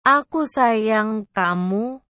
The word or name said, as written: aku sayang kamu